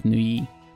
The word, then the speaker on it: Neuilly: We'll see how it actually goes, but it's spelled Neuilly, but I believe that it is pronounced something like Nuyi.